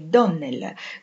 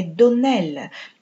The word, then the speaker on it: Donnell: «Clarice Almira mi ha detto che lei pronunciava il nostro nome Donnell con l'accento sulla O. Ora, signorina Shirley, la pronuncia corretta del nostro nome è Donnell con l'accento sull'ultima sillaba.